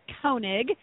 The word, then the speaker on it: Koenig: The last name is Koenig. It's not pronounced the German way like Sarah does on This American Life.